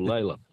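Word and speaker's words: Lalor: Oh, Lawler. Or Layla. – Layla. – Lawler's how it's supposed to be pronounced, but Layla's the other way.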